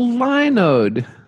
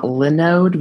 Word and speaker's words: Linode: I think you're supposed to pronounce it Linode and not Linode, because if it gets too close to Linux, it might run afoul of some legal thing. So they probably call it Linode. It sounds nicer as Linode anyways.